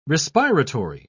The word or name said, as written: respiratory